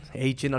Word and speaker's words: Achane: ya se hace oficial incluso que Devon Achen, Achen, ahora sí, lo, ahora pronuncié bien.